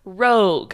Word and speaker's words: Roeg: directed by Nicholas Roeg.